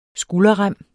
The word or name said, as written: skulderrem